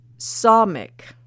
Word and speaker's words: Samekh: But the Hebrew letters were Ayin, and that's spelled A-Y-I-N. Ayin. Or Ayin, sorry. See? And the second letter is, it's spelled S-A-M-E-C-H. Or sometimes it's spelled S-A-M-E-K-H. And from what I understand, it's pronounced Samic. And the third letter is pronounced Kof. And it's spelled Q-O-F.